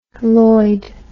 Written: Loyd

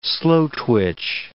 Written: slow-twitch

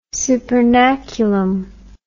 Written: supernaculum

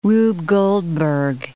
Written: Rube Goldberg

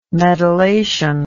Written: medullation

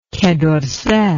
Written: quai dorsay

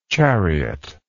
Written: chariotlike

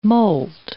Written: moult

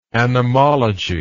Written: anemology

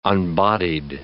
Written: unbodied

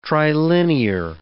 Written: trilinear

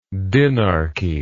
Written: dinarchy

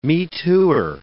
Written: me-tooer

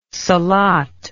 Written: salat

